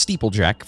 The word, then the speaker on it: steeplejack: A head for heights is advantageous for those hiking or climbing in mountainous terrain and also in certain jobs such as steep jacks or wind turbine mechanics. You know what a steep jack is? – No. – Excuse me. It's steeplejack. First of all, I mispronounced it. Steeplejack.